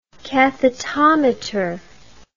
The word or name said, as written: cathetometer